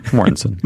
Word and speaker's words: Mortensen: How do you say his name? – Mortensen. – Mortensen. I call him Mortensian.